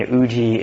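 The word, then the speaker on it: Uji (有時): So Arutoki is like in, you know, the Japanese way of pronunciation, and Uji is the Japanese version of Chinese pronunciation.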